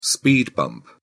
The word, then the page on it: speed bump: British English